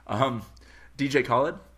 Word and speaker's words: DJ Khaled: DJ Khaled. I don't know how to say that. I hope I'm saying that right. – That's right.